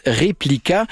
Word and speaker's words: RPLK: Vous ne le sauriez pas en voyant ça s'écrit R-P-L-K, mais ça se prononce Replika.